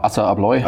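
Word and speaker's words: ASSA ABLOY: Ein gutes Jahr bei Essa Abloy, oder wie bin ich ausgesprochen? – Genau, Essa Abloy.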